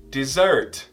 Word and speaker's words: dessert: E sobremesa, por mais que se escreva com dois S, a pronúncia é com som de Z. Dessert.